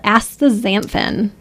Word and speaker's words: Astaxanthin: But we want to talk about a product called Astaxanthin,